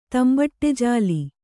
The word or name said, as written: ತಂಬಟಜಾಲಿ